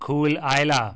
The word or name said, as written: Caol Ila